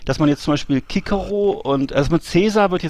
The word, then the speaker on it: Cicero: Also Cicero wird jetzt Kikero ausgesprochen und Cäsar wird Kaiser ausgesprochen. Ist wirklich wahr.